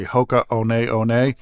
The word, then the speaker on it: Hoka One One: It's a little tricky because the company that owns Hoka, the name looks like Hoka 1-1, but the ones are pronounced One. So it's really Hoka One One.